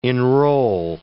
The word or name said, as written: enrol